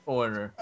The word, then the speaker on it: Horner: Mm, am I saying this right? James Horner. I, I, yep. Horner.